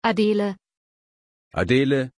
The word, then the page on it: adele: German